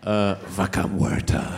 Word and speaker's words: Vaca Muerta: How do you pronounce that? – What is it? Vaca Muerta.